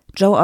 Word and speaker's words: Gio: Das sage ich jetzt auch richtig, weil das wird eigentlich geschrieben Gio, also Gio.Lucia. – Und wir haben extra nochmal nachgefragt, wie der Name ausgesprochen wird. Und das Ganze wird Joe ausgesprochen, weil Joe nämlich italienische Vorfahren hat und da gerne den Bezug zu Italien hätte. Und in Italien schreibt man das so und spricht es aber Joe aus.